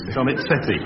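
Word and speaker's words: Donizetti: No, put it down phonetically for us. – Steve will know. Donny Chetty. Does that strike a chord at all? – Give or take it. Yes, Donny Chetty.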